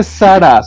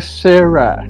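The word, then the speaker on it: Zara: No se dice Sara, se dice Sarah.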